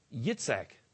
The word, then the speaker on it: Yitzhak: Even today, the Jewish pronunciation for Isaac is Yitzhak, with the emphasis on the last syllable.